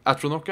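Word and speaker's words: Atronach: første gang jeg møtte sånn Flame Antrock. Åssen uttaler man det? Flame Atronock. Atronock, ja.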